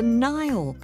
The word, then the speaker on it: Nile: Well, it's the River Nile, which flows through 11 different African countries, including Egypt and Ethiopia. One way you can remember the name Nile is that it sort of rhymes with crocodile.